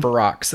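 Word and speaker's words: Ferox: Another cannibal movie I want to talk about real quick is Cannibal Farrow from 1981. – Farrox. It's actually pronounced.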